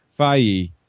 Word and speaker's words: Feige: And, you know, Kevin, is it Feige? I always say his name Feige